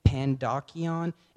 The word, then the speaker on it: Pandocheion: Pandocheion. It's P-A-N-D-O-C-H-E-I-O-N.